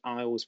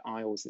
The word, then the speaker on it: Eyles: So that's British Eyles Productions. And as I said, Eyles is E Y L E S, which is a, um, how my surname is spelled.